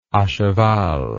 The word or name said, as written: acheval